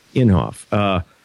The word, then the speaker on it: Inhofe: one of the sponsors was Senator is it Inhofe? I can never pronounce his name. – I think so. – Inhofe.